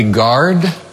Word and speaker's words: guard: That would be guard. The U is silent.